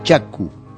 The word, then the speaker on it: Chaco: Los cronistas han aclarado que la pronunciación original era Chacu, deformada por los españoles, como siempre, en Chaco.